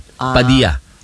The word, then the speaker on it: Padilla: Sa mga Espanyol at mga Amerikano, ya yun. Padilla. Parang pollo loco. Double L. – Paella. – Villa. Hindi Villa. Villa. Pero hindi Padilla.